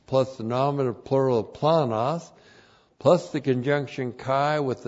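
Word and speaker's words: planos (πλάνος): And then we have the adjective planos. Looks like that in the Greek, that's P-L-A-N-O-S, planos.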